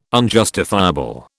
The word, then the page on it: unjustifiable: British English